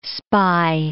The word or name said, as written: spy